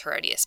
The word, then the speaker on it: Herodias: Sounds like odious, right? So odious Herodias. That is how you pronounce Herodias, by the way.